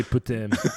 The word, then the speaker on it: putain: Well, it's actually putain. That's how you're supposed to say it properly.